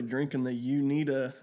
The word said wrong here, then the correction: Uinta: Uinta? it's a soft U. It's a U, a soft U. INTA.